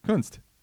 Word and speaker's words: Kunst: How do you say that, her last name? – Kunst. – Yeah, that's what I said. Kunst. It's like Shannon Dunst, but Kunst.